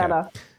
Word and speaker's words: Daraa: how do I say this? Daraa, is it? Daraa, okay. Daraa.